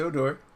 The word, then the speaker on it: Odor: It's Odor.